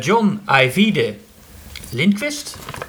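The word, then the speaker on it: John Ajvide Lindqvist: John Ivide Lindquist.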